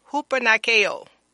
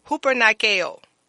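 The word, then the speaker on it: hupernikaō: And the word, I'm going to spell it for you, and then I'm going to pronounce it. It's spelled H-U-P-E-R-N-I-K-A-O. Again, let me spell that for you. It's H-U-P-E-R-N-I-A-K-O. And it's hupernikeo, hupernikeo.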